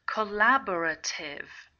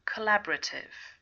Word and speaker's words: collaborative: Collaborative. Collaborative. When you say it quickly, you don't really pronounce the O. Collaborative.